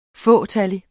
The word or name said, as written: fåtallig